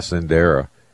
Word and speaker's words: Sendera: Yeah, I think it's spelled S-E-N-D-E-R-A, Sendera.